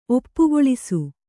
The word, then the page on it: ಒಪ್ಪುಗೊಳಿಸು: oppugoḷisu